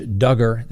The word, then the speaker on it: Duggar: Next on the docket, the prosecutors tell the defense attorney for Josh Duggar, that's right, rhymes with hugger, this is a simple case.